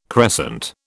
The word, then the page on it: crescent: British English